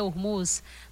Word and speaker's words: Hormuz: Eu estava até pensando aqui, meu Deus, a pronúncia correta é Hormuz, mas é o Estreito de Hormuz,